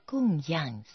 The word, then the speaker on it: 供养: 附带说明一下供养三宝的样这个字的发音，自古我们中国人对上是讲供养第四声，对下是讲教养养育，养是读作第三声，这里是供养三宝读作第四声样。